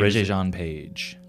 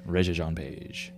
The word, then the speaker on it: Regé-Jean Page: Regé-Jean Page. – Well, there you go. I just call him the Bridgerton dude. – The Bridgerton dude. Regé-Jean Page.